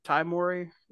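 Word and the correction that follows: Tahj Mowry: That's how you say it, Taj Mowry? – Yeah. – I thought it was Ty Mowry. – No, Taj Mowry.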